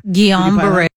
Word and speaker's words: Guillain-Barré: Guillain-Barré, it's Guillain-Barré.